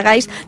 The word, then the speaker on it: gai: I ja que fem una secció de la llengua i parles ara de gais, recordem que gai s'ha de pronunciar així i no gay, que seria en anglès.